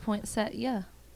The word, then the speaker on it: poinsettia: I say poinsettia, but I just looked up that it's actually pronounced poinsettia.